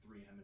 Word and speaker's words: 3M: But all it is 3 millimeter. Did I say that right? – No, it's 3M is the company.